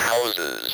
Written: houses

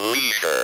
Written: leisure

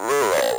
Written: rural

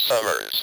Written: summers

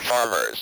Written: farmers

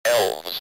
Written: elves

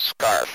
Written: scarf

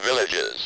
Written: villages

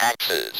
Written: axes

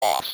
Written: loss

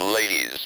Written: lady's

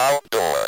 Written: outdoor

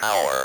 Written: our

